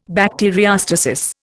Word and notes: bacteriostasis: Indian

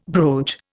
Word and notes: brooch: Indian

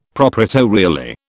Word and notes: proprietorially: British English

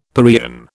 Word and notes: paeon: British English

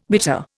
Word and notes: bitter: Indian

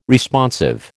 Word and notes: Responsive: American English

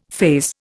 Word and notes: faze: Indian

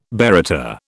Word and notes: baryta: British English

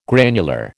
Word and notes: granular: American English

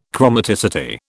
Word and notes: chromaticity: British English

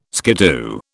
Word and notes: skiddoo: British English

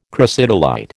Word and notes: crocidolite: American English

